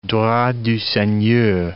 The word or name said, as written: droit du seigneur